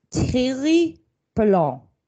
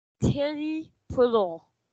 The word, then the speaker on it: Thierry Paulin: So today we are doing Thierry Paulin versus I Can't Sleep, which is, yes? – I looked it up on how to pronounce it. – Yes. – It's Thierry Paulin. – Thierry Paulin.